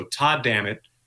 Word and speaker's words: Todd Dammit: So Todd Dammit rhymes with an expletive. Taking the Lord's name in vain.